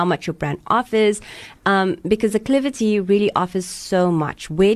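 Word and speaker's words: Aklivity: No, acclivity.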